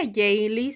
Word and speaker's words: Heyeli: Pues bueno, aquí vivo con mis abuelitos desde hace un tiempo y mi nombre es Nisi Jaylee, pero bueno, se pronuncia Jaylee según, pero se escribe Heyeli.